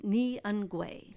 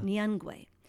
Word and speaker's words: Nyungwe: One of the places that we're going to is the Nwangi. And I say that incorrectly each time. It's pronounced Nyungwe. – Yeah. – Nyungwe.